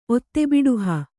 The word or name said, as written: ಒತ್ತೆಬಿಡುಹ